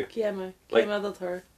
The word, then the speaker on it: Guillermo: Do I not pronounce the L? – No. – Is it Guillermo? – Guillermo del Toro.